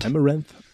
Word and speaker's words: Amaranth: Spricht man die so aus? – Amaranth.